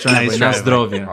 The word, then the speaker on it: na zdrowie: So I don't know if you remember from before, but we taught you how to say the Polish way of saying cheers. – Yes. – Which is na zdrowie. – Yes, nice driveway.